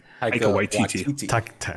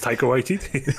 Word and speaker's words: Taika Waititi: Pirate show by, I'm always going to say his name wrong, Tiki Wahidi. – Taika Waititi. – I don't know. – Well, we just said it. It's Taika Waititi.